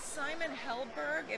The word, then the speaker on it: Simon Helberg: And then lastly, Simon Helberg, am I saying his name right, from the Big Bang Theory? – Yeah.